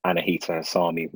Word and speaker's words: Annahita Hessami: So this week we've got Anahita Hasami with us from Cut Glass Studio.